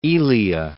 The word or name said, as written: Zeno of Elea